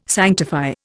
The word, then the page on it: sanctify: Indian